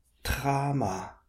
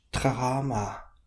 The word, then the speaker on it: trama: Trama, trama, that means along the shore. So that's got two long A's. Tra and ma, trama.